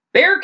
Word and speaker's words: Bexar: And when the radio station went, Bear County, I went, oh, that's how you say that.